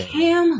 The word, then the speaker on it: Kham: Kham who rhymes with ham.